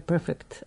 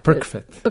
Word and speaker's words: Perfect: Izložba u galeriji Distribucija, to je nekad bio supermarket, We Are Perfect, ali to tako se ne izgovara. – Da, Perfect.